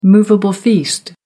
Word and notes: movable feast: American English

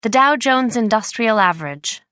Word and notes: dow-jones industrial average: American English

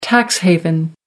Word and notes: tax haven: American English

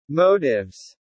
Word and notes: motives: British English

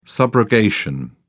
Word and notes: subrogation: British English

